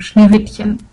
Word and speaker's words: Schneewittchen: And as I said, I'm from Germany, and I just had to let out my inner Hermione and tell me that it's actually pronounced Schneewittchen.